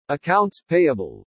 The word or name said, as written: ACCOUNTS PAYABLE